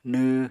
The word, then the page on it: nw: Apatani